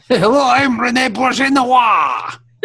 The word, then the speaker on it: Auberjonois: Auberjonois. – And that's how to pronounce his name.